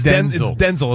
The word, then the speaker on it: Denzel: And Denzel, didn't he say that everyone's been saying it wrong? Yeah. It's like, it's Denzel? – It's Denzel. His mom calls him Denzel.